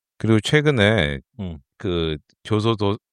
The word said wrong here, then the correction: 교도소: 발음이 안 돼. 교도소 네.